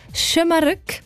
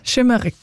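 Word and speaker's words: Şımarık: Also man sagt Schömerück und das heisst übrigens so ein bisschen frech, ungezogen.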